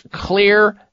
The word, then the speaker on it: Xlear: These are all places where you can purchase clear nasal spray. And clear is spelled with an X. The X in the word clear, X-L-E-A-R,